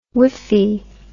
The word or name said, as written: whiffy